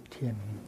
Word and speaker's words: thiền: And there is a tradition of meditation, the school of meditation, and we call it thiền tông. Tông is a tradition, a school, thiền tông. And we know that the Sanskrit term is dhyana, and the Vietnamese pronunciation of dhyana is thiền.